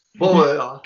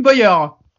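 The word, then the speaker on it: Bowyer: Comment tu le prononces ? – Bauer. – Clint Bauer.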